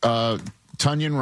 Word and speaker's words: Tonyan: They got Robert Tonyan, rhymes with Funyan, to go along with the wagon, Darnell Washington, and of course Freiermuth.